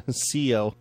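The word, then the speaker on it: Cioe: And also Terrapin will be at Garcia's in Port Chester, New York, with special guest lead sax player from the Rolling Stones, Crispin Cleo. I didn't pronounce that right. It's Crispin Cio.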